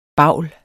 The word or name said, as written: bavl